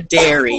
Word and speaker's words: dairy: A dairy. You have to say it right. She grew up on a dairy. It's called a dairy.